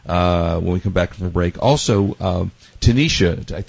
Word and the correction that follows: Tanita: it's probably pronounced Tanita, who came out with those digital scales. – The scale people are Tanita, yeah.